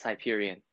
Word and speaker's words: Hyperion: Yeah, it's Hyperion.